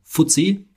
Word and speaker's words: FTSE: Vanguard würde das super gerne haben, dass wir ihre ETFs empfehlen, sondern von dem Indexanbieter, von dem Indexanbieter, das ist FTSI, ausgesprochen FTSE.